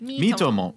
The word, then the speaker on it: Miitomo: How do you pronounce it? – Miitomo. – Miitomo. – It's like me and then Tomodachi.